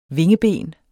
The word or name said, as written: vingeben